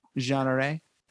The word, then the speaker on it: Jeanneret: I've seen a number of Jeanneret chairs. How do you pronounce that? – Jeanneret.